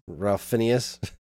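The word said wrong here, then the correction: Ralph Fiennes: No, that's not how you say his name. – Ray, Rafe Fiennes. Fiennes.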